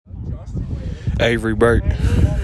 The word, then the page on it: Avery Burt: AY vry BERT